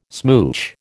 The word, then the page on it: smooch: American English